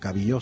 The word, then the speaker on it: cabillaud: Bueno, cabillo se pronuncia, escribe cabillaud.